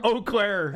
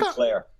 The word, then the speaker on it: Eau Claire: Eau Claire. – And how do you spell that? It's spelled all weird, isn't it? – E-A-U. Yeah, any word that's all vowels. – E-A-U. The E-A-U fuck? Eau Claire.